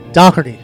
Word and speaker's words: Doherty: Doherty. Yeah, it's an Irish pronunciation.